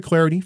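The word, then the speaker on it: Clardy: Hi, I'm Troy Clarity.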